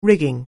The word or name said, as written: rig